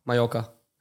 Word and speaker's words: Mallorca: Malaya? – Mallorca. – Malaya? – Mallorca. – Ich war Malaya. Er hat Malaya gesagt. – Ja, ich war Malaya. – Das ist falsch gesagt.